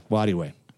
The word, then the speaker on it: Nwadiwe: Iffy Nwadiwe. The N is silent. That's the trick. – That one always bumps me. – Yeah. – Yeah. Nwadiwe.